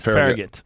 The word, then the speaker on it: Farragut: Farragut? – Farragut. Yeah. – Also spelled Glasgow, C-O-E or C-O-W.